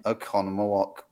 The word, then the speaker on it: Oconomowoc: Okay, so the first town where apparently The Wizard of Oz screened ever was the Strand Theater in Oconomowoc, Wisconsin. Did I say it right? – You did.